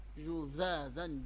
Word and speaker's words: جذاذا: ذكريني بها، واحدة منكم تذكرني أولا وقبل كل شيء، قبل أن ننتقل إلى الهمزتين، جذاذا، خلوا بالكم من الذال والثاء والظاء، ردوا بالكم، ضروري من الصوت يطلع النطق السليم في الحرف، جذاذا جذاذا هكذا، ترى قولوا جذاذا